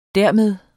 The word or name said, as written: dermed